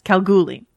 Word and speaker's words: Kalgoorlie: Oh, Kalgoorlie? – Well, there's an R in there, but okay.